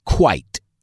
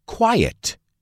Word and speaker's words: quite: Quite se pronuncia quite. No la confundas con quiet, silencioso, que se pronuncia quiet.